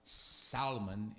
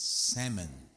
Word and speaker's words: salmon: It's not Solomon, it's salmon. The L is silent.